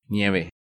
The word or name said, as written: Nieve